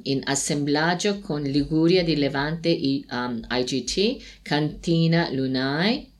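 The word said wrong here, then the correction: Lunae: Lune. In latino non si legge la... – Ah, quindi... – Questo è latino, lune. – Lune. – Lune, devi dire.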